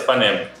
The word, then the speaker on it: Panem: No, no, no, no filme, a pronúncia é essa, Panem.